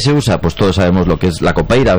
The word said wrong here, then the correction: Capoeira: ¿verdad? Capoeira. Qué mal pronuncio últimamente.